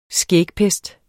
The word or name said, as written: skægpest